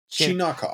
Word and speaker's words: Chinakhov: Chinakhov. I've been saying Chinnikov.